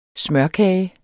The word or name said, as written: smørkage